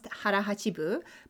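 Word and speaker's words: 腹八分: So today we're talking about a concept that's popular in Japan, and it is pronounced harahachibu, but literally translates to 8/10 of your stomach.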